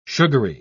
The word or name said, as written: sugary